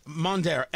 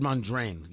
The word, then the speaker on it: Mondaire: Mondaire, M-O-N-D-A-I-R-E. I said Mondraine. Yeah, I always get the N moved around. Mondaire, M-O-N-D-A-I-R-E. That's his name. Congressman Mondaire Jones of New York.